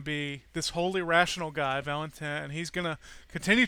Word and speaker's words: Valentin: Anyway, in the very first Father Brown mystery, you get this trifecta of Father Brown and the police detective, and I get to pronounce this in French too, Aristide Valentin.